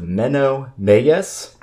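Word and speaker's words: Menno Meyjes: And the screenplay is written by, I'm going with Menno Meyes. That's my pronunciation. I apologize for the faithful. – I support that. – Who... I believe that is correct, actually.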